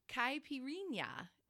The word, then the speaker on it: caipirinha: and I'm going to do my best to pronounce it correctly, is a caipirinha. And I don't say it as cute as she does. – That was good though. That's good.